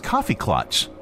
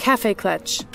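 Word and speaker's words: Kaffeeklatsch: That's coffee klatch. Coffee klatch. Is that how you pronounce it? – Cafe klatch. – Cafe klatch. Okay, I always thought it was coffee because they're served coffee.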